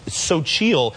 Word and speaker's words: Xochitl: It's so chill is how it's pronounced, but it's XOCHITL.